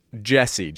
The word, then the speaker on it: Jesse: Judge Hodgman, it's pronounced Jesse. J-E-S-S-E, Jesse.